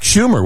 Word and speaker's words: Schumer: I believe that was Chuck Schumer, was it not? – Yes. Yeah. The senator's name rhymes with rumor.